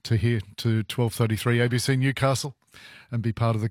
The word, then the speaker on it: Newcastle: But, yeah, I'm standing hard and fast on Newcastle, mate. There is no Newey. If you phone into this program, if you're on this program, it's Newcastle. It's not that hard. It's three syllables.